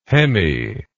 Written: hemi

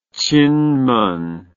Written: chinmen

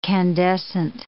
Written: candescent

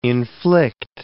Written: inflict

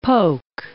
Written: poke

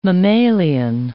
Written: mammalian

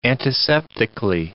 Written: antiseptically

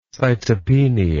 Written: cytopenia

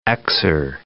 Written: Xer

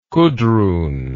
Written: kudrun

